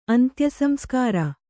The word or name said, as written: ಅಂತ್ಯಸಂಸ್ಕಾರ